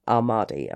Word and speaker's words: Ahmadi: Partly because as an Ahmadi, am I saying that correctly? – Yeah. – As part of that, as an Ahmadi Muslim, you were under threat.